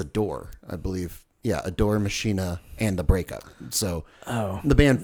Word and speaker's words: Machina: Machina, I believe, is the Latin.